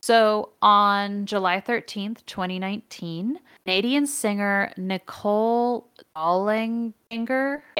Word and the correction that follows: Dollanganger: I am very bad with pronunciation. It's Dollanganger.